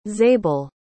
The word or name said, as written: zabel